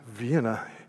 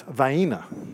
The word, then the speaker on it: Vienna: And so Vienna Boys Choir, and unless you're from where I grew up, and it's not Vienna, seriously, it's Vienna. There's a city, really village, close to where I grew up, in the metropolitan area where I grew up, and it's spelled Vienna, but it's Vienna.